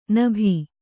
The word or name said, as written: ನಭಿ